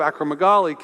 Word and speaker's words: acromegaly: Well, Malcolm Gladwell suggests that possibly the reason he was so big is that he had a malady called acromegaly. Sorry, I didn't pronounce that quite right. Acromegaly.